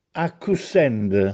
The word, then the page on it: Accussènde: Castellanetano